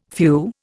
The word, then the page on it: phew: Indian